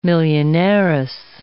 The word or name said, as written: millionairess